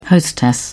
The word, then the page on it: hostess: British English